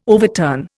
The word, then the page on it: overturn: Indian